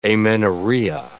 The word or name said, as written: amenorrhea